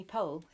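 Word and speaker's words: Powell: I put me in mind a tiny bit of Anthony Pohl, who I, thanks to you, now have to pronounce. – Anthony Pohl, let's walk over.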